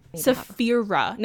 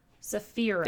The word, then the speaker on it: Saphira: Saphira. No, N. – Saphira. – R, not N. – Saphira. – There we go.